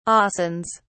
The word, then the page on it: arsons: British English